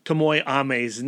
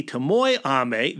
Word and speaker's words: Tomoe Ame: But now that we know that it's Lady Tomoe Ame, that is Stan Sakai's pronunciation of it as well, we're going to go with that.